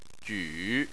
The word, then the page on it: ju3: chinese